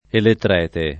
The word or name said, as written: elettrete